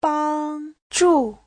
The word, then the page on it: 帮助: bang1zhu4